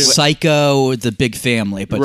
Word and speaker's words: Saiko: It's not P-H-Y. – Spelled like Psycho, like Psychopath. It's spelled like S A I K O.